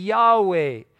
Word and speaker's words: Yahweh: But historically it was a W sound. They've changed that with modern Hebrew. So it was, if it is those vowels, it would be Yahweh, would be the correct pronunciation.